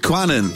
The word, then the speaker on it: Qanun: Rhymes with Gannon. – Cannon. – Quannon. – Quannon, yay! – Q-A-N-U-N.